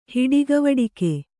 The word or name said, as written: ಹಿಡಿಗವಡಿಕೆ